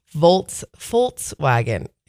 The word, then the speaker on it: Volkswagen: So if you want to say, oh yeah, and then there's one more, Volkswagen, the right way, you pronounce the V as an F. So Volkswagen. It's not going to stick with me.